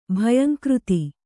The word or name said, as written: ಭಯಂಕೃತಿ